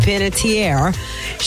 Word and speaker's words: Panettiere: So, Hayden Panettiere. Lord, I can never say her name. Her Hayden last name. Panettiere. – Panettiere.